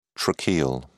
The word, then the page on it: tracheal: British English